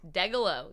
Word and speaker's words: Degelow: And your last name. – Degelow, yep. – Three syllables.